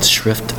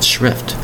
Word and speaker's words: shrift: That would be called shrift attention to something. Little care or little sympathy about something. Very little opinion about something is called shrift. S-H-R-I-F-T. Shrift. One syllable. It's pronounced shrift. S-H-R-I-F-T.